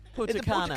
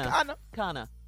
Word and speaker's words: Punta Cana: And I'm getting ready for Steve Harvey's Sand and Soul Festival in Punta Cana. Is that how you say it? – Punta Cana. – Punta Cana.